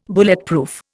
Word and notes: Bulletproof: Indian

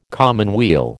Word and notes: commonweal: American English

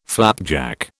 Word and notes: flapjack: British English

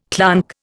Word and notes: clonk: Indian

